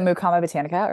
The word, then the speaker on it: Mukama Botanica: You are are owner of uh, Mukama Botanicals.